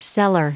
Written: cellar

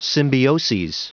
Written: symbioses

